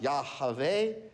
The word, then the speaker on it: Yahweh: Now, anytime in your Bible, when you see the name Lord with all capitals, capital L-O-R-D, this is the name. Sometimes pronounced Yahweh, Yahweh.